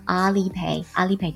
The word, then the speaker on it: Alipay: 你能够告诉我什么什么？我们来看一下。第二人回答说，You can use Alipay or WeChat. 你可以使用支付宝或者微信。 Either is okay. 两个任选其一都是可以的，两个都可以的。好，Alipay 注意一下读音呢，Alipay 支付宝哦，支付宝这样说，Alipay。